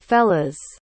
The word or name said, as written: fellas